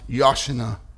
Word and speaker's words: Hosanna: word, Hosanna, actually pronounced Yashana.